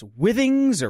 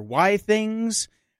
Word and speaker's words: Withings: If it's Withings or Y-Things, I always just say Withings because it sounds like a snooty European butler. Withings, right. – I would like some Withings.